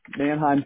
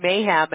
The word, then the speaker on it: Manheim: I almost at first said Mayhem, and I'm like, that's not Mayhem.